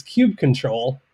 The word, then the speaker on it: kubectl: K-U-B-E-C-T-L.